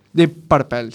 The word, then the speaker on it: Deep Purple: Deep Purple. Deep Purple, se dice así.